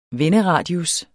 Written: venderadius

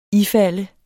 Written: ifalde

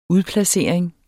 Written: udplacering

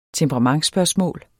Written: temperamentsspørgsmål

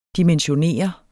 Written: dimensionere

